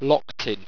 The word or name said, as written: loktinn